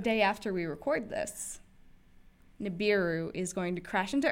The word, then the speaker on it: Nibiru: I feel like I should pronounce it Nibiru now. – No, it's Nibiru.